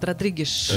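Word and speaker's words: Rodrigues: Это Мицуро Мицухару и Жао Родригеш. То есть лучший шеф Португалии, лучший шеф Южной, Латинской Америки. – Правильно произносит, Родригеш. Это как по-португальски, да? – И поэтому очень известные ребята.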